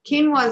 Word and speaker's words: quinoa: Quinoa is that grain that's spelled with a Q and pronounced like a K.